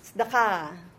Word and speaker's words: Sedaka: The accent is at the very last syllable. Ka. Sedaka. Not Sedaka. Sedaka. Sedaka.